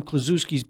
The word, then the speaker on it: Kuczynski: Yeah, and I knew some Kuczynskis, but I don't know if they're related. – Kuczynski is how you pronounce it. – Kuczynski, yeah.